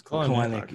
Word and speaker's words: Kelenic: It's Kelenic.